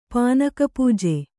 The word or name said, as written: ಪಾನಕಪೂಜೆ